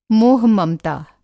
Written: मोह-ममता

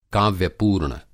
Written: काव्यपूर्ण

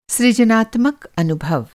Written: सृजनात्मक

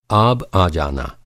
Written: आब

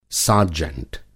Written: सार्जेंट

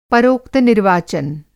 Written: परोक्त-निर्वाचन

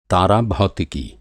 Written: भौतिकी

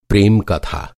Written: प्रेम

